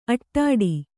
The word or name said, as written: ಆಟಿಡು